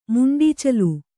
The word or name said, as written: ಮುಂಡೀಚಲು